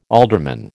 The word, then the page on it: alderman: American English